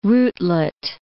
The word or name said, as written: rootlet